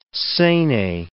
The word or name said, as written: sene